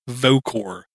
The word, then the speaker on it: Vocor: And I saw a Maradroid was offering the, I believe it's pronounced Vocor, V-O-C-O-R 1.0 piece of hardware.